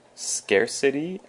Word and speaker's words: scarcity: First we have scarcity and scarce. Scarcity and scarce. So scarcity is a noun with the emphasis on the first syllable.